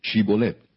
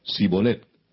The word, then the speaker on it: Shibolet: Si él respondía: No, entonces le decían: Ahora pues di Shibolet. Y él decía: Sibolet, porque no podía pronunciarlo correctamente.